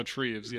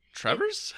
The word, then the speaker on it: Treves: And who did I tell you you were mispronouncing the name of yesterday? – Treves. – Oh, Treves, yes. – But that's also because I saw how it was spelled and I was like, Trevers?